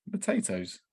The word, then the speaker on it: Matatoes: Well, it's like potatoes, but with an M. Matatoes.